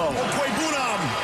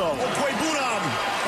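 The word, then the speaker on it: Okwuegbunam: Kuei Bunam. – Okay, that sounds terrible, right? Does everybody agree that this sounds terrible? – It does not sound like the proper pronunciation.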